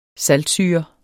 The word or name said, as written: saltsyre